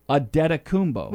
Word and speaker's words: Antetokounmpo: They try to pronounce the N and the T, and it's really Antetokounmpo.